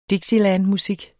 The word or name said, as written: dixielandmusik